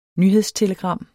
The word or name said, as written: nyhedstelegram